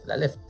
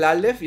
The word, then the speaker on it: Aleph: Aleph, ¿no? – El Aleph. – No, ese es como, que de hecho es de sus obras más. Es que se escribe Alep, PH, Alep, pero bueno, se dice Aleph.